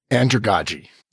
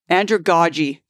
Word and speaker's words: andragogy: How do I say that word? – Andragogy. – Andragogy. Thank you.